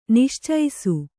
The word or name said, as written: ನಿಶ್ಚೈಸು